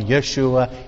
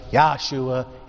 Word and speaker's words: Yeshua: Should we call him Yeshua? Yahshua? It is not Yahshua, by the way. Please do not do that.